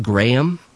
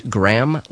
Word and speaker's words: Graham: I'm told that's pronounced Graham, not Graham, like the cracker, through Sierra Leone and Liberia.